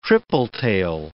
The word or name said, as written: tripletail